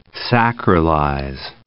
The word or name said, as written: sacralize